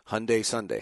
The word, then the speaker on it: Hyundai: I had like this note in front of me, Hyundai Sunday, Hyundai Sunday, Hyundai Sunday.